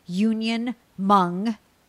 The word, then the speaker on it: Union Hmong: And the same handle on social, at unionmong, the H is silent, kitchen, at unionmong kitchen.